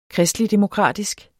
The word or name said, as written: kristeligdemokratisk